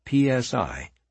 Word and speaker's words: psi: I do this by investigating psychic phenomena, often abbreviated as PSI, pronounced psi.